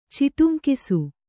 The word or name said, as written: ಚಿಟುಂಕಿಸು